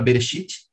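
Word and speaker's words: Bereshit (בראשית): Então, essas são as letras da palavra Bereshit, assim que se forma a palavra Bereshit, tá? Bereshit Shit. A letra Aleph não se pronuncia em hebraico, só para vocês saberem, tá?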